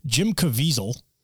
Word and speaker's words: Jim Caviezel: Jim Caviezel.